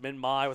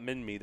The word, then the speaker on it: Minmi: I know it's probably pronounced Minmi with a long I, but I like Minmi because it sounds like mini, and that's what this is, a mini ankylosaurid.